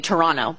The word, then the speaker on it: Toronto: They needed to go to the emergency goaltender, and the emergency goalie in Toronto, Toronto, because I know that y'all are very sensitive about that. In Toronto, you don't pronounce the T.